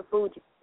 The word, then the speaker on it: Fugees: And she was blessed to be raised among the greats like the, how do you say it, Brick City, the Fugees? – Yep, the Fugees.